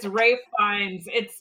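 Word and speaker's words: Ralph Fiennes: It's Rafe Fiennes. It's pronounced Rafe not Fiennes. The way that it is spelled at all. – Yeah, which really trips me up. Yeah, Rafe Fiennes.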